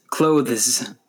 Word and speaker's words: clothes: The word has one syllable. The E is silent and the S makes a Z sound. Be careful not to say clothe is. Clothes.